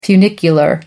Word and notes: funicular: American English

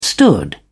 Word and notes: stood: British English

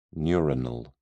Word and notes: neuronal: British English

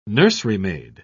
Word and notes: nurserymaid: British English

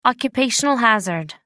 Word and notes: occupational hazard: American English

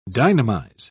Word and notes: dynamize: British English

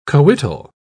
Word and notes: coital: British English

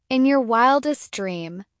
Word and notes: in your wildest dream: American English

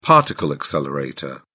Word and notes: particle accelerator: British English

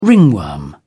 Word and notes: ringworm: British English

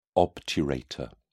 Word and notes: obturator: British English

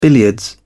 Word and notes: billiards: British English

